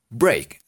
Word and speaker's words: break: Entonces, break se escribe así. B, B de burro, R, E, A, K. Va de nuevo. B, R, e A K y se pronuncia break como hey break break que sería romper.